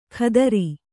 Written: ಖಾತ್ರಿ

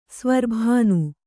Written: ಸ್ವರ್ಭಾನು

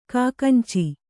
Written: ಕಾಕಂಚಿ

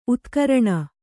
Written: ಉದ್ಗರಣೆ

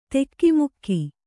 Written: ಧಕ್ಕೆಮುಕ್ಕೆ

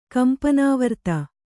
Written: ಕಂಪನಾವರ್ತ

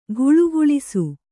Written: ಘುಳುಘುಳಿಸು

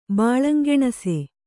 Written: ಬಾಳಂಗೆಣಸೆ

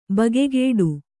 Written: ಬಗೆಗಿಡು

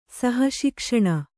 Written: ಸಹಶಿಕ್ಷಣ

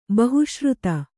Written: ಬಹುಶ್ರುತ

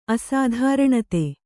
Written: ಅಸಾಧಾರಣತೆ

